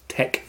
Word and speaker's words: TeX: One of the most famous and long-lived ones has been TEX, T-E-X, always pronounced TEC. T-E-K-I, the Greek letter, or LaTeX, designed by Donald Knuth, who I talked a little bit about in the previous lecture.